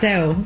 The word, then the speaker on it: Adele: He came and asked me how I say my name, and I was like, Adele.